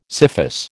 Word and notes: scyphus: American English

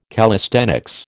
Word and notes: calisthenics: American English

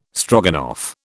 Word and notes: Stroganoff: British English

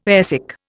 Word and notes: airsick: Indian

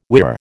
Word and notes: whirr: American English

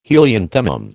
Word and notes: helianthemum: American English

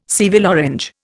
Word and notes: Seville orange: Indian

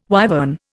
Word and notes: wyvern: Indian

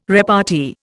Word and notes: repartee: Indian

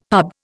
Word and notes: pub: Indian

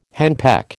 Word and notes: henpeck: American English

